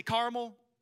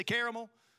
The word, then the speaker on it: caramel: Here you probably say caramel, but I'm from South Carolina, so we say caramel.